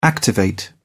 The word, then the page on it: activate: British English